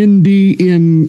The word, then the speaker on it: NDN: but they're called the Indian Collective, which it's spelled letters N-D-N.